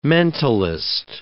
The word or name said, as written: mentalist